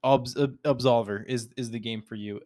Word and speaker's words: Absolver: But yeah, no, Absolver, if you've never heard of it and you like fighting games, check it out. You spell it A-B-S-O-L-V-E-R. So it's Solver, but A-B at the beginning. So Absolver.